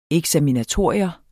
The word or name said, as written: eksaminatorium